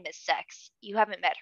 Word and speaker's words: Xhex: No, it's spelled like X-H-E-X. So it could be like Sex. – Stop it. – But it's essentially Sex.